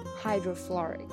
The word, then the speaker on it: hydrofluoric: 那氢氟酸这个词怎么说呢？Hydrofluoric.